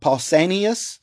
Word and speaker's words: Pausanias: According to writings from a man named Pausanias, that's spelled P-A-U-S-A-N-I-A-S.